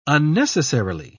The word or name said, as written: unnecessarily